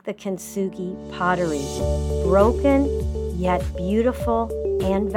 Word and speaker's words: Kintsugi: I want to encourage you to do a search on images of Kintsugi pottery. Okay, let me spell it for you. K-I-N-T, Kintsugi, S-U-G-I, Kintsugi pottery.